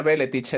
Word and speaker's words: Belletich: Espero haber dicho bien tu apellido, Gianfranco. ¿Qué tal? – ¿Cómo sí, estás? sí, se pronuncia Beletich.